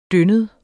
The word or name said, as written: dyndet